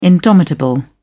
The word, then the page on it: indomitable: British English